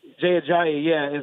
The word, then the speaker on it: Jay Ajayi: They got Jay Ajayi, I believe is how you say his name. – Jay Ajayi.